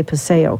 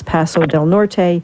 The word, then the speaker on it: Paso del Norte: and I always want to say Paseo, because in Tucson we say Paseo, but that's El Paso. So sorry, Esther, if I mispronounce that, but it's Paso del Norte, who are working with groups to address the issue of hotspots for heat resilience in border cities along the Mexico U.S.